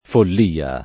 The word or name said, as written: follia